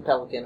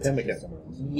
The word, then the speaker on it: pemmican: We get to take the pelican with us. Not pelican. Whatever that stuff is. Meat. – Pemmican.